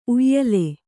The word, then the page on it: ಉಯಿಲು: uyilu